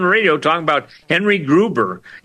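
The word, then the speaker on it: Gruver: Groover, by the way, is spelled G-R-U-V as in Victor, E-R, not Gruber with a B as in Bravo. G-R-U-V as in Victor, E-R.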